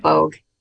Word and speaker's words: Boge: Alyssa Boge.